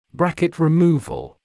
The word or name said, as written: bracket removal